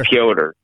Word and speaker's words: Piotr: Just call him Piotr. – Piotr. Yeah, Piotr. That's right, exactly. Not Peter, Piotr.